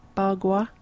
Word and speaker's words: Bagua: The most common being Ba Gua. Do you think I've pronounced that right? Ba Gua. – Sounds about right. – B-A-G-U-A.